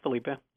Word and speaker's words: Felipe: It's Felipe.